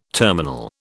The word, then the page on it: terminal: British English